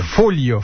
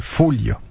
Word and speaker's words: Foglia: Foglia, faut dire. Foglia. Les Italiens ne prononcent pas. Le G est muet devant le L, comme bottiglia.